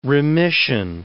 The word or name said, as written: remission